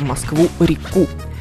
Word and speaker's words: Москву-реку: Подчеркну, что с названием главной столичной артерии есть еще одна тонкость – это ударение. Правильно говорить «в Москву-реку».